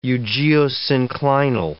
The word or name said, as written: eugeosynclinal